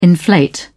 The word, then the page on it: inflate: British English